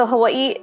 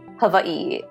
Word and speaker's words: Hawaii: Yeah, so Hawaii, it's, you pronounce both the I's. Hawaii, yeah. – So wait, why have I been calling it Hawaii?